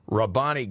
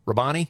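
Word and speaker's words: R'Bonney: Humiliated contestants claimed the 2022 Miss USA pageant was rigged and that the winner of the contest, Miss Texas, Rabani Gabriel, I think that's how you say her name, David, it's capital R apostrophe capital B O N N E Y. Rabani? – I think you're safe with that.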